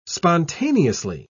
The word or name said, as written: spontaneously